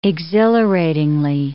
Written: exhilaratingly

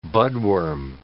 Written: budworm

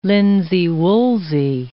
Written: linsey-woolsey

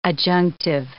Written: adjunctive

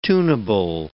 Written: tunable

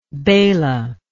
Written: bela

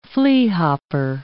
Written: fleahopper

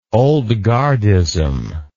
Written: old guardism

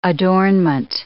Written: adornment

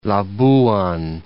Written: Labuan

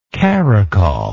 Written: caracol

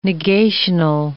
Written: negational

